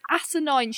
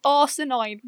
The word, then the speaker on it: asinine: You can't even pronounce asinine. She pronounces it arsenine.